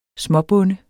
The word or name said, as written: småbonde